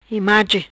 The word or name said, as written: Image